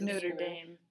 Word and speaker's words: Notre Dame: Well, yeah, it is Notre Dame.